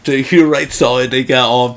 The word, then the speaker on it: Hutterites: I keep wanting to say Hunterites. – Yeah. – Because it's spelled like H-U. – Right. – But it is Hutterites, walking out of a GNC with a bunch of, like, protein.